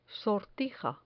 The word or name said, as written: sortija